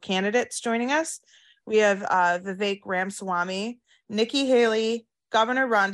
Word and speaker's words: Vivek: I got to give you a shout out, by the way, for pronouncing Vivek's first name correctly. You're one of the few people, so you get a big thumbs up on that one. It's Vivek like cake, as he likes to tell people.